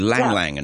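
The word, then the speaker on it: ylang-ylang: There's another one called Lang Lang, and it's spelt with a Y at the beginning.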